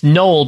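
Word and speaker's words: Knowle: It's Noel, but it's K-N-O-W-L-E. – I've seen that before. – I've never seen that in my life. – No, that's how I would suspect to spell it. – Not N-O-E-L? Not Noel? – No, Noel with a K.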